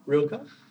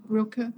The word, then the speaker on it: Rilke: Rilke? With a bit of an A sound at the end.